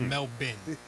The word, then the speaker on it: Melbourne: The Australian pronunciation, Sal, is Melbourne.